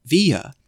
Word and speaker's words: Villa: My full name rhymes, not Leah Villa, but actually Leah Via. Or, as I would have said as middle schooler, my last name is pronounced like tortilla. Or maybe I would have said to think of Pancho Via.